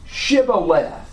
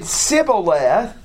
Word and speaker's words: Shibboleth: And they told them to pronounce Shibboleth. And this talks about an ear of grain, or that term means an ear of grain. And if they couldn't pronounce it correctly and said Sibboleth, they ended up taking their life.